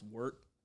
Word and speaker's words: wort: So it's spelled W-O-R-T. It's kind of pronounced wort, but. It's spelled W-O-R-T, wort, okay. But it's wort, pronounced okay. Like W-E-H-R-T, wort.